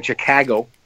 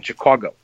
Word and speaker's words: Chicago: There's the northern one, which is closer to like the Wisconsin, right? Chicago, and then there's a southern one, Chicago, right? Both of which are completely correct pronunciation, Chicago or Chicago, but you know, there's a distinct difference between the two.